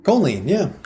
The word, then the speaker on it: choline: But there is something, and help me how to pronounce this, C-H-O-L-I-N-E, is in the yolk, not the white. Mm. Is that choline? Choline? – Choline, yeah.